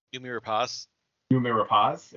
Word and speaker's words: Noomi Rapace: but Nome Rapace, I cannot pronounce her name. – Noomi Rapace. – Yeah, so Noomi Rapace.